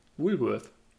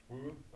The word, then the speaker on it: Woolworth: Äh, der, Na der Laden Woolworth, wie würdest du den aussprechen? – Woolworth. – Woolworth.